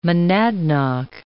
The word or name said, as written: monadnock